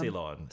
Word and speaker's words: Ceylon: Yeah, because they added, so in the original, they had a song called, I say it wrong, what, Ceylon? – Ceylon.